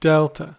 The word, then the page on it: delta: American English